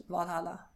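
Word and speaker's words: VA-11 HALL-A: Det uttalas Valhalla.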